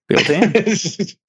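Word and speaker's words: Biel-Tan: Is it Beltan? Is that how you say it? – Yeah, it is now. – Beltan.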